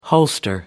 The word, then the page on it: holster: American English